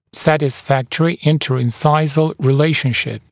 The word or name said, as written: satisfactory inter-incisal relationship